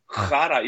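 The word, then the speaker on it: Chara: Jag gjorde ju det faktiskt för något år sedan när vi hade Boston att jag gick in och lyssnade på Shara, hur det skulle uttalas. Och det är Shara. Då körde jag ju Shara i hela införstudion.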